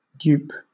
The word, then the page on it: dupe: British English